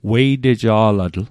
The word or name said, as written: weiteja'latl